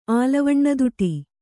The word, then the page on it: ಆಲವಣ್ಣದುಟಿ: kannada